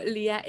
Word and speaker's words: Lía: Entonces, por eso Lía, etcétera.